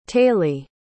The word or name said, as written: taylie